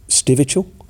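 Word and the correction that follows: Stivichall: Steichel. – Steichel, that's it.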